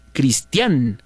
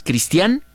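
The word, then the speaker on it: Cristián: Nati, es correcto, mi nombre lleva acento en la letra A, Cristian. Por eso es Cristian con acento.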